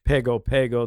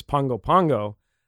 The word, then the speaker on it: Pago Pago: Well, it's not really Pago Pago. The correct pronunciation is Pongo Pongo.